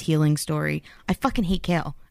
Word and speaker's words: Chaol: It's spelled C-H-A-O-L, by the way. – Kale? Charcoal? – Chol. I want to say Chol so bad.